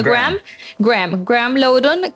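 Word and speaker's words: Graeme Lowdon: No, mire, Graham Lawton, no sé, el nombre se pronuncia Graham Lawton,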